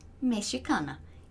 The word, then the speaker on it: Mexican: Presta atenção em como se pronuncia essa palavra. Em português a gente diz mexicana, em inglês Mexican, Mexican, ok?